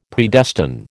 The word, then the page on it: Predestine: American English